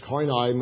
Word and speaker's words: koine: Koine Greek, the word koine, pronounced koine, means common.